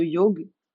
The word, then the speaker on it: Yawhg: Moi, je vous recommande fortement, si vous ne connaissez pas le jeu The Yogue, alors c'est extrêmement chiant à écrire, mais c'est vraiment extrêmement bien.